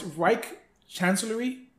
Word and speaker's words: Reich Chancellery: It's Reich Chancellery. – Okay. – That's Reich Chancellery is essentially the White House of Germany.